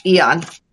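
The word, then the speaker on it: AEON: Yes, Eon.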